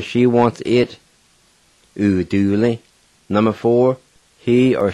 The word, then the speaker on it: uduli: And it's spelled A-N-A-T-L-I. A-N-A-T-L-I. Number three, he or she wants it. And it's spelled U-D-U-L-I. U-D-U-L-I. Number four, he or she wants them. D-U-D-U-L-I. D-U-D-U-L-I.